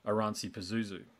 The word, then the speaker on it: Oranssi Pazuzu: So just when you think you've heard it all, along comes, and I hope I pronounce your band name correctly here, but Oranssi Pazuzu.